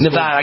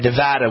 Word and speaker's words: Nevada: Nevada, I got to say it right, Nevada. They always get mad at me in Nevada if I say Nevada. It's always attaboy, Nevada, atta, Nevada. There we go.